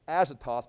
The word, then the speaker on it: Ashdod: Ashdod, it's pronounced here Ashdod, but it's the ancient city of Ashdod.